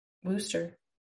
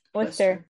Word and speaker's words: Worcester: Worcester. I keep saying it wrong. Worcester. – Worcester.